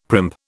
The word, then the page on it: primp: British English